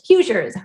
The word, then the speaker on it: Hoosiers: How do you say Hoosiers.